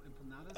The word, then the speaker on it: empanadas: Two caramel apple empanados. Right? Did I say that? Empanadas. – Empanadas.